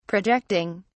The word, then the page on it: projecting: British English